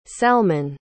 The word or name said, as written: salman